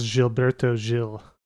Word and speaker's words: Gilberto Gil: It's worth saying, I think that it's pronounced Gilberto Gil. – You are correct.